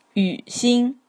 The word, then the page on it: Yǔ-xīn: 宇新; yi:w ʃi:n; Pinyin; Chinese